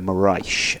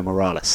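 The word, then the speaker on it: Morais: We were talking about the Triple Crown in episode 38, and I mispronounced the surname of the Portuguese surfer Frederico Moraes and called him Frederico Morales.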